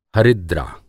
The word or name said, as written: हरिद्रा